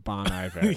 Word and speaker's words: Bon Iver: Oh, I think I used to pronounce the name as Bon Iver. – Bon Iver, yes. – Okay. – I think it's French.